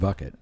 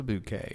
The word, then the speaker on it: bouquet: It's a large bucket. – It's it's a bouquet. It's fancy. – Depends on how you pronounce it. – Bouquet.